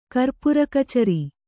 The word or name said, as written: ಕರ್ಪುರಕಚರಿ